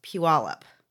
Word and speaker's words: Puyallup: No, it's actually pronounced Puyallup. Yeah. – There is extra vowels there.